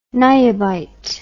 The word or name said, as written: niobite